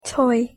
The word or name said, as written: toy